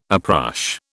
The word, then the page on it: uprush: British English